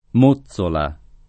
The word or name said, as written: Mozzola